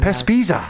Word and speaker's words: Pespisa: Pespisa.